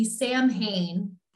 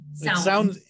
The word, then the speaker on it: Samhain: So Samhain, which if you're looking at the word itself, looks like it should be Samhain. – Oh, that's not how it's pronounced.